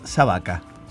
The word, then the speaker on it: sabaka: Perro en ruso se pronuncia sabaka.